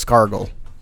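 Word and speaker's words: Skargle: Scraggle. And that's S-K-R-A-G-L-E. – One second, can you spell that again one more time? – Okay, so that's S-K-A-R-G-L-E. It's kind of like gargle, but not, but scargle.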